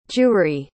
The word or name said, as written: jewry